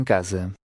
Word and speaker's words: casa: When an S has a vowel before and after it, it's pronounced Z, as in the English word zeal. – A caridade começa em casa.